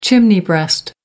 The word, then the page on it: chimney breast: American English